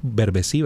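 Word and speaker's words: Berbesí: Tú lo pronuncias Berbecí, ¿verdad? – ¿Y es así? Así me lo enseñaron a mí. – ¿Berbecí? – ¿Es así? – ¿Es así? – Sí, es Berbecí. – Muy bien.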